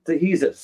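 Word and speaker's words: De Jesus: No, I pronounced Noel's surname wrong. He said it's Noel Dehesus. Sorry about that. I pronounced the G when I shouldn't have done.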